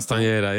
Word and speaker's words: Castañeda: En jij kwam met een Colombiaanse harpist, Edmar Castañeda, zeg ik dat goed? – Castañeda, ja.